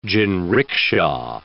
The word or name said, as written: jinricksha